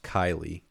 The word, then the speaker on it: Kaili: I think it's pronounced Kylie, K-A-I-L-I.